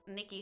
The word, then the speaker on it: Nicky: you can find me on OnlyFans at Nicky Hugs.